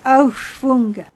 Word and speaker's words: Aufschwung: Mais c'est pas mal du tout, Fantasiestücke. Et la pièce est Aufschwung.